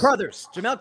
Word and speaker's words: Crouthers: Did I say that right? – Crowthers, close. – Crowthers, Jamel Crowthers. Yes.